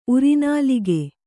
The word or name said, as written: ಉರಿನಾಲಿಗೆ